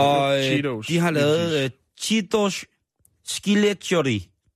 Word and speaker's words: Cheetos: der må du hjælpe mig med at udtale det, Cheritos. Det er dem, som laver cheesy, det hedder Cheetos.